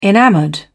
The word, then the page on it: enamoured: British English